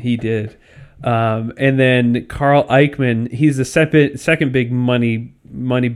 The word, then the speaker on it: Icahn: Carl Icahn, that's how you pronounce it? – Yep.